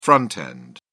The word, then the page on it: front end: British English